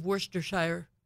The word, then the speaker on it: Worcestershire: And then you put two glugs of Worcestershire. Did I say that right? – Nice. – Yes.